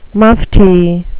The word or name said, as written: mufti